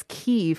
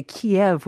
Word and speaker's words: Kyiv: Uh, not only that, um, even I noticed how you're pronouncing pronouncing the city as Kyiv, mm-hmm. which is mm-hmm. the original Ukrainian mm-hmm. pronunciation, right? And so we've seen a kind of correction in Korean media exactly. and also in overseas media, we don't we no longer say Kiev, which right. is the Russian pronunciation of that. So it's Kyiv as well.